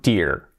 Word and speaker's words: Deere: No, pues, John Deere, John Deere. Es una marca, John, nombre, y Deere es un apellido. Suena como ciervo, pero no se escribe igual. Pero la pronunciación es Deere, exactamente.